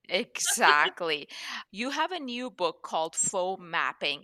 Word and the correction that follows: Foo Mapping: Is that how you say it? No, it's actually called Foo Mapping.